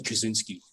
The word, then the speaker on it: Kaczynski: Is it pronounced Kaczynski or Kaczynski? – In America, they typically say Ted Kaczynski.